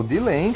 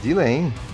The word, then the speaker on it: Delain: Por favor, é o Dilen, cara. Dilen. – Dilen, é exatamente. Não tem nada de Delaine, Line, nada disso. É o Dilen aqui, galera.